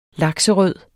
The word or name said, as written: lakserød